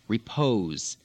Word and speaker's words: repose: Repose. – Repose. – Frequently, of course, this is spelled with an S, but in English pronounced Z.